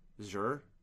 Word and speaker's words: Xur: And I'd seen a comparison for Destiny, folks, that he's kind of like Xur, I think is how you say it? X-U-R? Xur? – Xur?